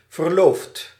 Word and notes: fiancée: [fijɑ̃.se]

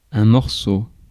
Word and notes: morceau: [mɔʁ.so]; France

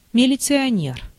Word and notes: милиционер: /mʲɪlʲɪt͡sɨɐˈnʲer/; militsioner

